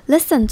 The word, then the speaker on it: listen: Listen 最前面的 li 念成短的 li 的感觉，然后 t 不发音，所以我们就忽略它，直接念成 listen 就可以咯。